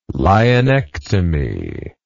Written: lienectomy

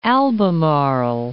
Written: Albemarle Sound

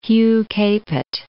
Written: Hugh Capet

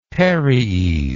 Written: paries

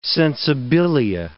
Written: sensibilia

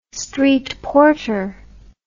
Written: streetporter

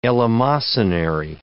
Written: eleemosynary

